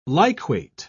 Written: liquate

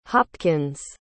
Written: hopkins

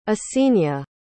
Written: yessenia

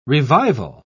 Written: revival

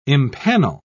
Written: impanel

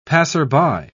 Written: passerby

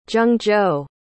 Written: zhengzhou

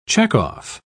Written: checkoff

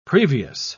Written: previous

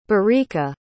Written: barika

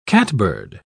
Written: catbird

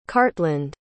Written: cartland

